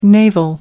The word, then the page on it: navel: American English